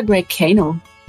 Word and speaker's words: Greycano: Ist es eigentlich Greycano oder Greycano? Habe ich mich letztens gefragt. – Ja, es ist eigentlich Englisch, auch Englisch ausgesprochen, Greycano.